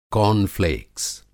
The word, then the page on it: कार्नफ़्लेक्स: کارن فلیکس; कॉर्नफ़्लेक्स